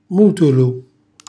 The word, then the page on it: mutulu: Calabrese